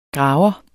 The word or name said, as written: graver